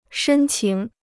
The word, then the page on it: 深情: shēn qíng